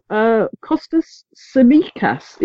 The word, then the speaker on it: Kostas Tsimikas: So Kostas Tsimikas is how I understand it's pronounced.